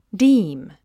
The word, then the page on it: deem: díːm; ディーム